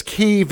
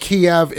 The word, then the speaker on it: Kyiv: Uh, and the whole discrepancy there is why people want to call it Kyiv versus Kiev, is because Kyiv is the actual Ukrainian name. Yeah. And Kiev is the Russian pronunciation.